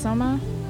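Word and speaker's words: Sohma: And then his name is pronounced Soma. – Yep.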